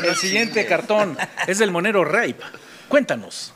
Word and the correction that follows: Rapé: No se dice rape, Chihuahua. El monero rapé hace un trabajo interesante de subliminal, donde se puede ver ahí al prócer saludando a Cienfuegos.